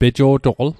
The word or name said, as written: pejo'toql